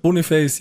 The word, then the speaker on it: Boniface: Wie, wird der so ausgesprochen? – Boniface, ja? – Boniface, ja, habe ich jetzt gelernt.